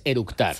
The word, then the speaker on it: eructar: y lo primero que hizo es orutar. – Con orutar es eructar. – Sí. ¿Vale? Así dice, ¿cómo se dice? – Eructar. – Eructar. – Sí, con tata C.